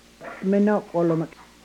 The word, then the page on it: menokwolumek: Yurok